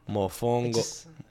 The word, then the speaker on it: mofongo: The emphasis is on a different syllable. Contra ka, as opposed to mofongo, right? – It's more on the second syllable, but you're going to have to make it work.